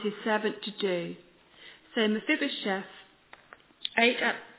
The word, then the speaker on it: Mephibosheth: Well, like our reading spoke about this morning, and Kelly so well pronounced, Mephibosheth.